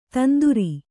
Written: ತಂದ್ರ

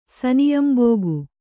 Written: ಸನಿಯಂಬೋಗು

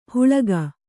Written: ಹುಳಗ

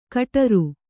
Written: ಕಟರು

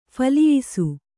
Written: ಫಲಿಯಿಸು

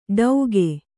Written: ಡೌಗೆ

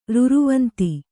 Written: ರುರುವಂತಿ

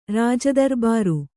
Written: ರಾಜದರಬಾರು

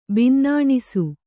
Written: ಬಿನ್ನಣಿಸು

